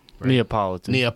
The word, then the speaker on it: Neapolitan: Because you got the Napoleon ice cream, if I'm saying it right. – Neapolitan. Close enough.